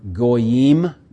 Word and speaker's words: Goyim: Like the word Goyim, G-O-Y-I-M.